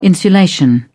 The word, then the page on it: insulation: British English